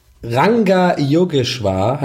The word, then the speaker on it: Ranga Yogeshwar: oh wie spricht man das? Yoga. – Rangeshwar. – Rangeshwar. Rangeshwar. Ranga Yogeshwar heißt er.